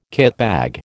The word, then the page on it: kitbag: American English